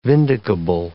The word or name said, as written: vindicable